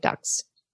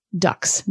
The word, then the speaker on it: ducts: On July 27th, they had finally prepared a dog with a successfully removed pancreas and a dog with tied pancreatic ducts. Ducts. Ducts. Just the way it's pronounced sounds like ducks.